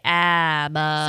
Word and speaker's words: ABBA: Sweden's pronounce it ABBA. Most ABBA. In the USA, ABBA. – So we are in the US of A, so we can say ABBA.